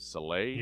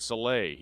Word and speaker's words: Saleh: And I hope I get his name right, Robert, is it Saleh? – Yes. – Robert Saleh, he's the 49ers defensive coordinator.